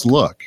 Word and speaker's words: LWK: There's a company, I think it's pronounced Luke. – Look, do you know? – Look. I would say look. It's Look.